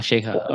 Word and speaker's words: Sheikha: Oh, Sheikha. Okay. Sorry, yeah, I pronounced that wrong.